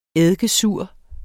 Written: eddikesur

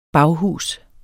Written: baghus

